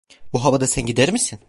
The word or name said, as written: gider